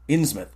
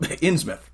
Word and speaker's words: Innsmouth: Innsmouth. – Yeah, I was going to make that a very strong mouth, and I was like, nope, Innsmouth.